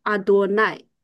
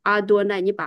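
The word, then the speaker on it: Adonai: 原来的那个叫阿多奈，阿多奈，你把阿多哦爱读进来，就是耶和华亚和卫啊。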